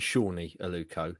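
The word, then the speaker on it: Sone Aluko: Just going back to Janoy boys, you know we've we've recently solved the uh, the Aluko mystery. It's definitely Shawnee Aluko.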